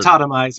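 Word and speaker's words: Autotomize: Charlie, as a command, is going to tell Katana to use automatize. No, that's not the way you pronounce it. – Automize, yeah. – Automize, there we go.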